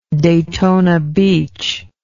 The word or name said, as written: Daytona Beach